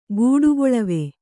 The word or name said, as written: ಗೂಡುಗೊಳವೆ